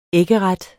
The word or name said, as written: æggeret